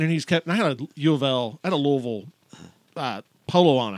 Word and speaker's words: Louisville: Because if you're going to say it right, it's Louisville. – Louisville. – It is Louisville.